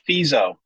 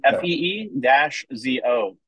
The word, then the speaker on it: Fizo: Before I say, how do you say Katie's last name from Tree of Hope? Fizo. – Fizo, like FEE-ZO. Fizo. – Fizo. – Phonetically spelling this puppy out.